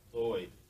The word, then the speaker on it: Lloyd: Lloyd. – Lloyd? – Lloyd. – Lloyd? – Yeah, L-L-O-Y-D.